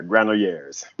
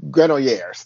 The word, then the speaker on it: Granollers: They took out Zabalas and Granet. I always say his name wrong. – Yeah, Granollers. – Granollers. Thank you very much.